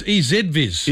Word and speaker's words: EZVIZ: It's EZViz. – It's easy. – We say Z. – Well, easy as in it's easy. – I know, EZ.